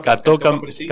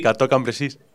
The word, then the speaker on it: Cateau-Cambrésis: Hasta la paz de Cateau, no sé cómo se dice. – Cateau-Cambrésis. – Cateau-Cambrésis.